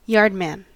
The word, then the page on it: yardman: American English